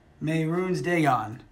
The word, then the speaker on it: Mehrunes Dagon: I have the pronunciation right there. – Mehrunes Dagon. – Yeah, there you go.